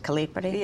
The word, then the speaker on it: Calipari: The Italian intelligence agency, Calipari, is that how you pronounce it, Calipari? – Yes.